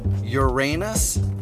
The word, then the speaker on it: Uranus: And even though the words are properly pronounced harass, Uranus, and nuclear, we're still putting the commute in commuter entertainment.